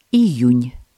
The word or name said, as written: июнь